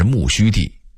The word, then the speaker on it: 木樨地: 木樨地的读音应该是木须地，原来此处为养马之地，种有大量的苜蓿，因此得名苜蓿地，后来改名木樨地等等。